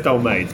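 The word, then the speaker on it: dolmades: Oh, thanks, some Dol Martins. – Yeah, yeah. No, it's pronounced Dol Maids, but yeah, whatever.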